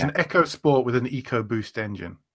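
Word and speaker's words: EcoSport: And the other one that really surprised me was the Ford, I believe it's pronounced Echo Sport, not Eco Sport.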